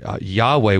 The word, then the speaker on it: Yahweh: Yahweh, we sometimes pronounce it. The old Jehovah, it was sometimes pronounced in the old days, but that name is indicated by the, in English, by the all capital letters.